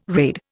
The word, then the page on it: raid: Indian